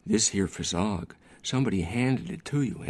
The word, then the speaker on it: phizzog: Fizzog, which is his own word, in which he spells P-H-I-Z-Z-O-G.